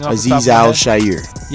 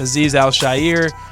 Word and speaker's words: Azeez Al-Shaair: Sean Murphy Bunting, Arden Key, the linebacker from San Francisco with a name who I have a hard time pronouncing. – Azeez Al-Shaair. – Yes, Azeez Al-Shaair.